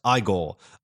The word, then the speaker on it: Igor: But it's not Igor, it's pronounced Igor, the grandson of Victor von Frankenstein's henchman, who is overjoyed to meet Frederick.